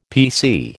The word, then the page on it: PC: American English